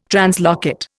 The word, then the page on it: translocate: Indian